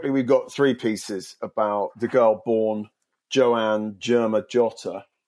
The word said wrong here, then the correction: Germanotta: Isn't it Germanotta? – Germanotta. All right, let me say that again. The girl born Joanna Germanotta.